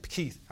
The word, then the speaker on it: Keith: I look, I said, I, Keith. I wrote my name in there, K-E-I-T-H. Amen? Most people spell K-I-E-T-H. They spell it the wrong way. But it's K-E-I-T-H.